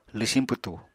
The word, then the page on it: lwsiñ pwtu: apatani